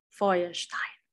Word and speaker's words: Feuerstein: Eh, Georg Feuerstein Georg Feuerstein eh, una mente ¿Cómo se pronunciaría según tú? – Feuerstein. – Porque tienes que acercarte más.